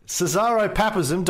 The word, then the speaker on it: Caesaropapism: I think I have a Caesaropapism. – I think I have a really bad case of Cesaro. How do you say it again? – Caesaropapism.